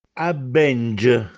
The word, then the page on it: Abbènge: Castellanetano